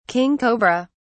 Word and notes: king cobra: British English

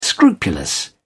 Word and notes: scrupulous: British English

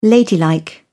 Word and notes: ladylike: British English

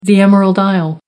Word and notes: emerald isle: American English

